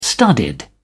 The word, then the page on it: studied: British English